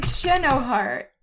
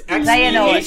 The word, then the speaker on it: Xehanort: It's, I thought his name was like Xehanort. – No, it's Xehanort. Xehanort. – When I was a kid, I called him like Xehanort because that's how you pronounce the X in Portuguese.